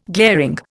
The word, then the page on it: glaring: Indian